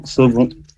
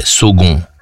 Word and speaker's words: Saulgond: Vincent, 27 ans, Saugon. – Eh ouais, Vincent, 27 ans. Ah oui, c'est comment c'est Saugon? – Oui. – Et ça s'écrit comment ça? S-A-U? – S-A-U-L-G-O-N-D. – Ah ouais, Saugon.